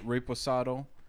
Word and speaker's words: reposado: Reposado embodies the flavorful characteristics of 100% Blue Weber Agave, distilled and aged to perfection in oak barrels.